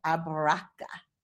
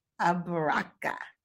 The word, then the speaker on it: abaraka: So to say thank you, you say abaraka. Abaraka. So you have to say it like, abaraka.